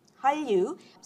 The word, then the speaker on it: Hallyu: Hallyu, some people spell it H-A-N-R-Y-U, others spell it H-A-L-L-Y-U, and literally is from Chinese, called the Korean Wave.